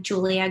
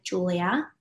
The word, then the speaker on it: Giulia: But my Instagram is I am Julia Guerreri. My name is spelled with a G actually. Mm-hmm. And TikTok is Manifest with Julia, G-I-U-L-I-A.